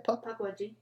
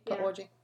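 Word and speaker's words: Pukwudgie: And is that how you say it? – Puckwudgie. – Puckwudgie. Yeah.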